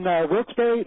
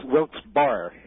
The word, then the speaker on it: Wilkes-Barre: That's the town I always pronounce Wilkes-Barre, right? – People from not around here do tend to refer to it as Wilkes-Barre. But that's how it's spelled, Wilkes-Barre. – Well, the guy who's named after, his name was pronounced Barry, but who knows? – Uh-huh.